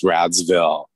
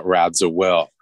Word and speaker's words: Radziwill: It's actually like Luann telling Carol, your last name's actually pronounced Radsville, not Radziwill.